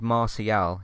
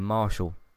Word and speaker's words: Martial: either because there's a there's a footballer actually called Martial. It's a French pronunciation, and a lot of people call him Marshall.